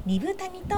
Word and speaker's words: 二風谷: 2 つの風の谷と書いて二分谷と読みます、